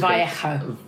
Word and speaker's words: Vallejo: And just the same as Jesus Vallejo, or I was Vallejo.